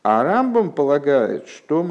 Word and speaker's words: Рамбам: Рамбана а, для того, чтобы не путать э, Рамбана и Рамбама, э, мудрецы специально решили, что Рамбана будут говорить с ударением на второй слог, а Рамбама на первый.